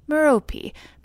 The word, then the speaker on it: Merope: Which leads me naturally to Sisyphus's wife, Merope. Probably actually pronounced Merope, but again I go with the Harry Potter pronunciation.